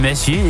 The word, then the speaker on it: Messier: En officieel is die naam in het Frans. Dus als je het helemaal wil uitspreken, dan is het dan Messier.